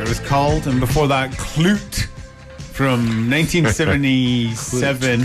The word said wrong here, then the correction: Clout: Come on. Clout. – Clout. Is that how you say it? – C-L-O-U-T. – Clute? – I say clout.